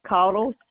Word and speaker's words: Caudle: My name is Denise Caudle, C-A-U-D-L-E.